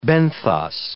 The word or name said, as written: benthos